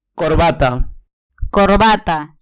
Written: corbata

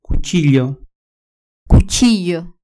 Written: cuchillo

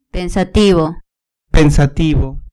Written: pensativo

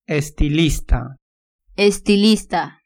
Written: estilista

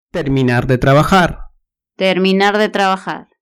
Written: terminar de trabajar

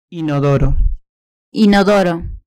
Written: inodoro

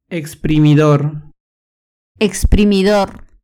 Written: exprimidor

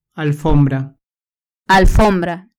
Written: alfombra

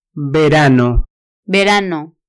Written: verano

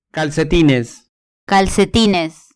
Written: calcetines

